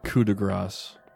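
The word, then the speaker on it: coup de grâce: So as a coup de grace, that is, hold on, am I pronouncing that right, or is it coup de grace? – I think it's supposed to be coup de grace.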